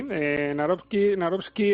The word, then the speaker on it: Nawrocki: El otro, Karol Nawrocki, no sé si lo he dicho bien. – Sí, Nawrocki es un historiador, director del Instituto de la Memoria Histórica.